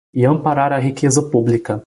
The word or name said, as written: amparar